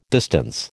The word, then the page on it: distance: American English